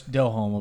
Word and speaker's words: Delhomme: I used to be a Jake DeLome fan. – That's Delhomme, I believe it's pronounced.